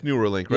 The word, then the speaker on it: Neuralink: Am I saying it right? It is Neuralink, right? – Yeah. – Neuralink.